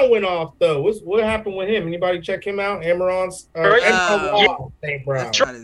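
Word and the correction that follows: Amon-Ra St. Brown: That's not how you say it. – Amon Ra St. Brown, yeah.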